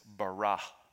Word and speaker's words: bara: Now, this word created is bara. Now, if I said this correctly, I would roll my R's.